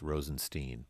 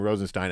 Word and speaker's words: Rosenstein: The Freedom Caucus wants to impeach Rod Rosenstein, whose office, by the way, told us a year ago that his last name is pronounced Rosenstein. But I'm gonna bow to pressure. – Oh, everyone says Rosenstein. – I know. Well, we called the office, and I talked to Mr. Rosen, whoever is assistant. How does he pronounce his last name? She said, without any doubt, Rosenstein.